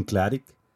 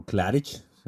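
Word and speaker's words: Klaric: que su nombre es Jürgen Klarik, o Klarich, así se pronuncia.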